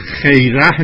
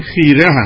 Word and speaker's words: خیره: این که شما خوندید آقای عبدی خیره نیست اصلا، این من نمیدونم این تلفظ رو شما از کجا به دست آوردید، این خیره هست